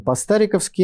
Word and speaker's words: по-стариковски: Дорогие друзья, мы продолжаем нашу программу по-стариковски, именно так, с ударением на букву А.